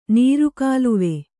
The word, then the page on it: ನೀರುಕಾಲುವೆ: nīru kāluve